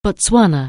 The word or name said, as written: Botswana